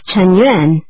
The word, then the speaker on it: Chen Yuan: My name is Chen Yuan. – How do you spell that? – It's C-H-E-N and Y-U-A-N.